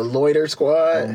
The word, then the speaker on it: Loiter Squad: Never watched the Loitier Squad or nothing like that. – Loitier. – How do you say that shit? – The Loiter Squad? – The Loiter Squad.